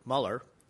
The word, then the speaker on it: Mueller: Bob Mueller is how it's pronounced.